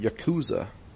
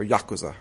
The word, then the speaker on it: Yakuza: Who is, the ultimate Yakuza. Or Yakuza, I should say. That's how you pronounce that.